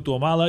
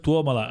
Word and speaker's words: Tuomala: Helsinki és quan Tuomala, que tothom diu Tuomala, i és Tuomala, és esdrúixola també.